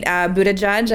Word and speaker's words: Buttigieg: Pete Buttigieg, I believe, is the correct pronunciation.